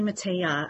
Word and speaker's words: Mateja: It's actually Mateja. I don't know if it's like silent or a Y, but you're close.